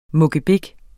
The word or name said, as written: mukkebik